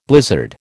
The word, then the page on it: blizzard: American English